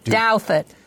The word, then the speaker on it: Douthat: Douthat.